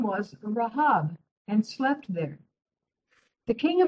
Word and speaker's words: Rahab: Some people call her Rahab. Her name is pronounced Rahab, who helped the Israelites at Jericho when Joshua sent the two spies to the land, Canaan.